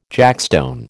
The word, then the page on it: jackstone: American English